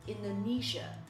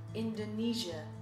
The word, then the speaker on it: Indonesia: 好，我们今天讲的主要是这个地名 Uluwatu, Bali, Indonesia。注意一下这个印尼的发音，重音是在第三个音节 Nisha, Nisha, Nisha, Nisha, Indonesia, Indonesia.